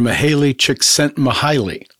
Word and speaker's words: Mihaly Csikszentmihalyi: So the positive psychologist, Hungarian-American Dr. Mihaly Csikszentmihalyi.